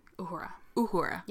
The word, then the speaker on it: Uhura: Uhura.